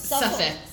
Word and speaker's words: Suffolk: which is in the 107 Suffolk. ¿Cómo se dice? How do you pronounce it? How do you pronounce it? – Suffolk. Suffolk Street.